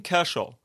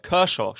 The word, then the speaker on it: Kershaw: Clayton Kershaw. Kershaw spricht man ihn, glaube ich. Ja.